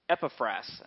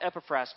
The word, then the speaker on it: Epaphras: We talked about Epaphras, and that's how you say his name. Remember, I couldn't say it before? Epaphras is his name. We talked about Epaphras going to see the Apostle Paul to find out one thing.